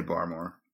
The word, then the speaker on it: Barmore: I think it's Barmore. Is how you say it? – Yeah, I say Barmore.